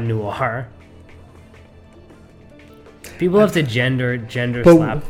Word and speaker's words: noir: What this movie is, though, is it's also classified as a how do you say noir? – Noir. – Noir. – N-O-I-R-E.